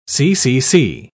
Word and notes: ccc: British English

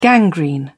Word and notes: gangrene: British English

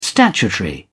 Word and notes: statutory: British English

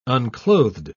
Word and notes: unclothed: British English

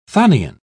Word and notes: chthonian: British English

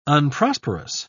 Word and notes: unprosperous: British English

